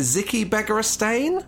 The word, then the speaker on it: Begiristain: I've never known how to pronounce it. Is it Ziki Begiristain? – Yeah. Pep's right-hand man, the director of football guy.